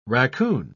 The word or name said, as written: raccoon